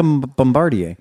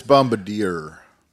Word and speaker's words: Bombardier: It looks like a lot like a Bombardier. But the... – The deposit's going to be $16,000 a piece. – It's Bombardier. – Bombardier. So Sorry, sir. It's Bombardier.